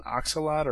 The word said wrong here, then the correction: axolotl: It's an axolotl.